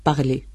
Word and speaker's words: parler: Parler. Al final tenemos ER, E sin acento. Pero ante la R final se pronuncia E, como si llevara un acento agudo, pero no lo lleva.